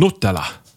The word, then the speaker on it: Nutella: Hur ska det uttalas då, tror du? – Nutella.